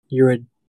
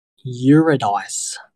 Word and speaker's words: Eurydice: and um, that movie just like takes a random classic old story, um, the Greek legend of Orpheus and Eurydice. – Eurydice. – I pronounced that wrong.